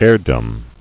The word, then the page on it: Heirdom: heir·dom